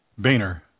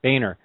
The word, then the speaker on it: Boehner: John Bromauer? – Boehner. – Boehner. – Boehner. B-A-Y-N-E-R. – Okay. – That's not how it's spelled, but that's how it's pronounced.